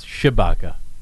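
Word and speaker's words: Tshibaka: She says rhymes with Chewbacca from Star Wars, but I don't know how to pronounce it really. But anyway, Chewbacca versus Murkowski. And, you know, that's what they're spending the money on. So, you're absolutely right. And thanks for making that point. – You're close. It's Chewbacca.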